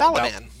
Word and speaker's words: Balaban: Bob Balaban.